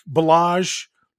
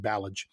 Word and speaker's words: Balazs: Pending finding out exactly what these salaries are, it could go down to one if someone like Balazs, that's how I was told to pronounce his name rather than Balage.